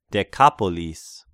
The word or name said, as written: Dekapolis